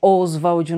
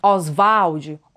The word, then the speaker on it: Oswald: ou Oswald, né? né? Não é Oswald numa pronúncia mais inglesada, mas Oswald, Oswald Andrade, bem abrasileirada mesmo.